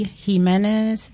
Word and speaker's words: Jimenez: Jimenez, that's on Facebook, and that's spelled J-I-M-E-N-E-Z, and it's connected to my Instagram and TikTok.